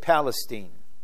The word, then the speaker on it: Palestine: And one, this is the Texas pronunciation, they were moving to Lake Palestine.